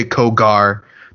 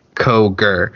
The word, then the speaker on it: Koger: And like, I remember at Otis Koger gave me a very long speech about how his name is pronounced to the don't say Kogar, don't say Cougar. I'll walk back through the curtain and make you do it again.